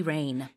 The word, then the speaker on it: rein: Rain이라는 거는요, 발음은 우리 하늘에서 내리는 비처럼 들리지만 스펠링은 지금 알려주신 대로 달라요. R-E-I-N, rain.